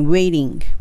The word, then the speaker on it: waiting: ここでの発音は、まあ、もちろん while っていうのが重要なんですが、えっ、ー、と、 waiting、これも先ほどの eating と一緒で、えっと、 waiting ってはっきり言わずに waiting どちらかというと L の発音になって OK です。